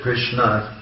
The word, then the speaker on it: Krishna: It's pronounced here the same in Hungary as it is in India, as it is in America, as it is in Russia. Krishna.